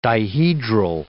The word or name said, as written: dihedral